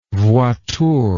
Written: voiture

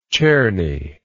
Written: czerny

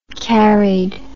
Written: carried

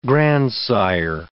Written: grandsire

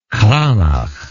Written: cranach

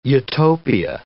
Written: utopia

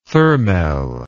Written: thermel

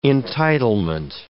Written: entitlement

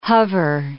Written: hover

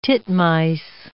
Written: titmice

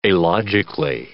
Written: alogically

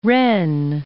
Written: Rennes

